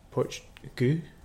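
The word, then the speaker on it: Poit Dhubh: Poich du, pronounced poch du,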